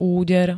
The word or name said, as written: úder